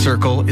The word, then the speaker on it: CRCL: CRCL, pronounced Circle, is a free mobile crisis response service serving the Comox Valley.